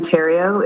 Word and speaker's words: Therriault: Chair Fitzpatrick and members of the committee, my name is Shannon Terrio. It's spelled T-H-E-R-R-I-A-U-L-T.